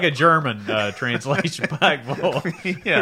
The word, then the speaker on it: Pikeville: And somebody says Pikeville. If they pronounce both the I and the vill very clearly, it's not right. It's Pikeville.